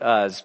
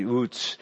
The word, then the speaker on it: Uz: We often pronounce it Uz, but I just looked it up last night, and it's actually Uz.